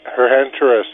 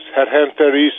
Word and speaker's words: Herhenteris: Herhenteris. Hedhenteris in Greek, man. Hedhenteris. Herhenteris spelt in English.